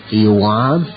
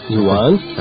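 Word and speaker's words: Yuan (圆): And round is Yuan. – What's that? All right, Y-U-A-N with a rising thing over the A. – Uh-huh. – So it would be Yuan. – Yuan. – Yuan.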